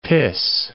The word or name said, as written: piss